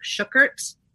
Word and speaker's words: Shukert: I think that like the actual German pronunciation of it is like Shukert. – Okay. – That's sort of how my grandfather would say it, but then it got more anglicized as time went on.